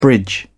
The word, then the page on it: bridge: British English